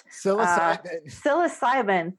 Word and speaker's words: psilocybin: Psilocybin, thank you.